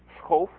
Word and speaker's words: Schoof: So Schoof is Dutch, as am I, so the right way to say his name is Schoof and not Schoof or things you hear usually.